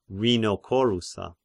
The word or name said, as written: Rhinocorura